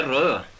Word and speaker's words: Rud: Hur skrivs det? – R-U-D. – Och de uttalar det? – Rö, men det gör vi inte nu. Det var när jag var liten som de sa Rö. Men nu säger de alltid Rud.